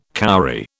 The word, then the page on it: cowrie: British English